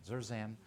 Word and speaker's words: Zerzan: That's why Jerick Jensen and John Zerzan, see, I got to say his name again, Zerzan.